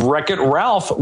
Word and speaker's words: Wreck-It Ralph: You have Wreck-It Ralph with a silent W.